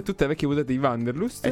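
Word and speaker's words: Wanderlust: Sì, non è semplice, perché è un termine tedesco. – Me lo rispieghi, come si pronuncia? – Vanderlust, che significa ossessione per il viaggio.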